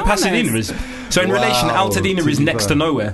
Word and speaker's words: Altadena: Altadena is near Pasadena. So it rhymes.